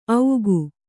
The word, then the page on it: ಔಗು: augu; Kannada